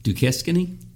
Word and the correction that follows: Duquesne: How do you pronounce that? – Duquesne. Yep.